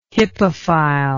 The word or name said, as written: hippophile